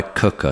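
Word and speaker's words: Quooker: Welcome to the Dear Lovejoy podcast, sponsored by Cooker, spelt quicker, pronounced cooker, 100 degrees boiling hot water, straight from your kitchen tap.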